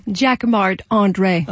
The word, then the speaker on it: Jacquemart-André: Jacques Marc André.